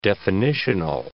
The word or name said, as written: definitional